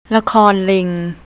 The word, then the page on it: lakornling: thai